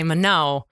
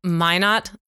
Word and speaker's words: Minot: See, I almost said it wrong. I started to say Minot. And it's Minot, North Dakota.